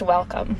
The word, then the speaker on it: WLCM: So our agency is pronounced Welcome. So it's spelled WLCM, but it's pronounced Welcome, and it's a long story, but yeah.